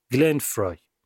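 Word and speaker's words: Glenn Frey: یه چیز تو پرانتز بگم، گلن فرای، گول اسپل اسمشو نخوریم، شبیه فری نوشته میشه اما تلفظ درستش فرایه، گلن فرای.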